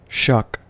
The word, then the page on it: shuck: American English